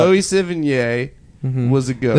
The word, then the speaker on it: Sevigny: Savini? – Yeah. How do you say it? – Chloe Savini? – No, it's not. – I said it right the first time, and now you've infected my brain with your weird...